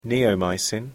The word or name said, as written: neomycin